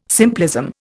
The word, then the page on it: simplism: Indian